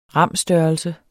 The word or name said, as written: ram-størrelse